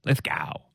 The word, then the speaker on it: Lithgow: And then there's big rain out Bathurst, Lithgow? How do we say that place? Lithgow.